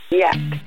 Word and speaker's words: Jack: Jack in Spanish is pronounced. – 95.7 Jack FM is pronounced Yak.